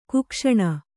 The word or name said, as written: ಕುಕ್ಷಣ